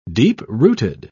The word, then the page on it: deep-rooted: British English